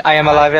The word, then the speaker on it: I Am Alive: Assim, ele é meio bugado, a dinâmica de escalar é meio ruim e tal, Mas é o I'm Alive, né, que você pronuncia? – I Am Alive é legal.